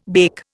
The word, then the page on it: bake: Indian